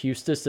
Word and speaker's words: Huestis: Yeah, Great Falls native, Mr. Josh Huestis.